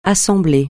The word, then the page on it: assemblée: francais